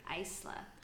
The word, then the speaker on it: Acler: I think so. Aisler. That's how I would say it. A-C-L-E-R.